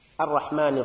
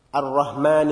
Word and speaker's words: Ar-Rahman: The second verse, Ar-Rahman Ar-Rahim. In Ar-Rahman Ar-Rahim, one very common mistake is the pronunciation of Ha. Again, we mentioned it already in in the first verse in Alhamdu. Ar-Rahman Ar-Rahim, Ar-Rahman Ar-Rahim.